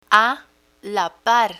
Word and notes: a la par: Spanish

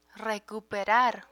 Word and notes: recuperar: Spanish

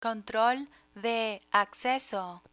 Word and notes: control de acceso: Spanish

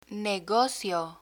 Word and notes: negocio: Spanish

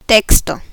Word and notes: texto: Spanish

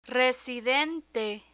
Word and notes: residente: spanish

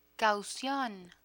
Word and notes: caucion: spanish

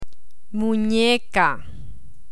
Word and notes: muneca: Spanish

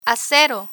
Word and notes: acero: Spanish

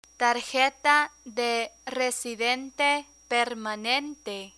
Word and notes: tarjeta de residente permanente: Spanish

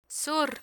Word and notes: sur: Spanish

